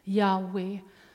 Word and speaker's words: Yahweh: Att när Gud väljer att presentera sig med detta namn, Yahweh, som man nu ändå tror att det ska uttalas, Yahweh,